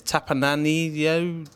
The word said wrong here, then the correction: Tapaninaho: I'm glad you read that, I'm afraid. – Tapaninaho. Christian Tapaninaho on the Flickr Guardian Tech Weekly group, and Daniel Pacey on the I Love the Guardians Tech Weekly podcast Facebook group.